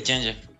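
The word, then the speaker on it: Jantje: ¿Dije bien el nombre de Janje? – Sí, Changer. Excelente.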